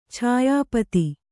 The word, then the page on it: ಛಾಯಾಪಥ: chāyā patha